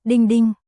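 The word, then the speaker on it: Ding Ding (钉钉): Nguồn, Wikipedia, Ding Talk, tiếng Trung, Ding Ding, phát âm, Ding Ding, là một nền tảng giao tiếp và hợp tác doanh nghiệp thành lập vào năm 2014, do tập đoàn Alibaba phát triển.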